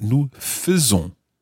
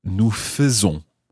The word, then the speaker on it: faisons: F-A-I-T. Now, something slightly funny happens with the nous form of this verb. Because although it's spelled the same way, F-A-I-S-O-N-S for the ending, we don't pronounce nous faisons, but nous faisons. Nous faisons. So that's F-A-I-S-O-N-S, nous faisons.